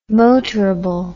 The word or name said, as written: motorable